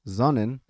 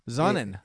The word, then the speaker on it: Sonnen: And it's actually pronounced Sonnen. – Sonnen. – In German, because we are a German, we're the U.S. subsidiary of a German company.